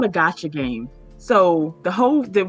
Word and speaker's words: gacha: it it's, it's spelled G A C H A.